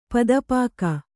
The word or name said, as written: ಪೊತ್ತುಪೋಕ